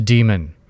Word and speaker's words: daemon: I went and checked out Oxford dictionaries online, and they have the daemon, D-A-E spelling, D-A-I spelling, D-E-M-O-N spelling, and when you look at the phonetics of it, they just say it's pronounced demon.